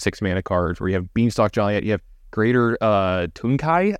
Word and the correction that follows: Tanuki: I think is how you pronounce it. – Tanuki, maybe? – Tanuki. Oh, yeah, it's a different one. – It's from Kamigawa, so it's got to be Tanuki.